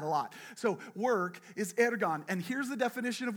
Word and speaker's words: ergon: It's pronounced ergon. Please do the tongue trill.